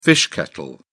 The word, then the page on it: fish kettle: British English